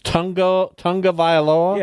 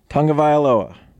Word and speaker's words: Tagovailoa: But the first four letters of his name are T-A-G-O. Why isn't it Tagovailoa? – Because it's not. – It's Tungavailoa? – Yeah. – T-U-N-G-A. When his name is spelled T-A-G-O. – Tungavailoa. – Well, make it make sense. – Different letters are pronounced different ways in different dialects.